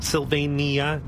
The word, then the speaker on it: Sylvania: Sylvania? I think it's Sylvania. – It's four syllables, Sylvania. Either way, we definitely see further.